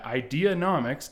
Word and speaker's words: Ideanomics: Now he's out in LA working as a managing video producer at Ideanomics.